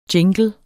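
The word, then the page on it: jingle: Danish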